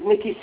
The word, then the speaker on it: Nicky: My name is Mickey Satuda. – I'm sorry, can you spell that for me? – Mickey, N-I-C-K-Y, Satuda, S-C-O-O-D-E-H.